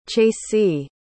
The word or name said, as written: chasye